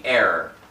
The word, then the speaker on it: heir: Nothing will provide to you riches of eternal value aside from your relationship with Jesus Christ, because He is the heir of all things. That's H-E-I-R, which I just pronounced as E-R-R-O-R. So don't get confused on audio.